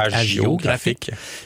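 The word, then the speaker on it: hagiographique: Ça s'écrit H-A-G-I-O, graphique, agiographique.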